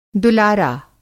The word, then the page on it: दुलारा: Hindi